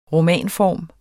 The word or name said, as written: romanform